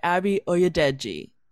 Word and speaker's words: Abi Oyedeji: We're joined by Abi Oyedeji. Yes, fuck yeah. Okay, great. It's exactly how it's spelled, everybody, but I still, you know, when you start recording, you can fuck things up real easy.